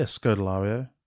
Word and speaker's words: Scodelario: Kaya Scodelario. Scolidario? Scoliosis. Scod. – Kaya. Yeah, Scodelario.